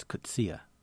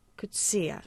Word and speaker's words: Coetzee: Coetzee. Do I say it right? No one ever knows, do they? – Actually, he came here, and it's Coetzee. – Coetzee. Yeah, I thought there were three syllables, you know, you look it up online, it gives you one phonetic spelling.